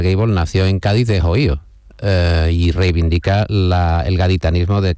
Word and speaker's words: Ohio: Tengo un amigo que es de Cádiz, mi amigo Antonio Hernández Rodicio, y él pronuncia no Ohio, dice Ohio, con lo cual tiene mucho más punto,